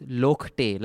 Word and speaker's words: Lochte: He says, I've mispronounced Ryan Lochte. It's Lokte, like Aapte or Aamte from Marathi surname.